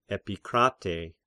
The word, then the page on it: Epikrates: Epikratei